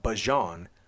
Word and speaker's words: Bijan: The player himself told us how he pronounces his own name. He said, quote, "I go by Bijan, but I call myself Bijan. So I'm going to stick with Bijan."